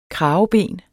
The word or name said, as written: kraveben